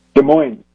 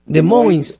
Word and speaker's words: Des Moines: Des Moines. – Des Moines.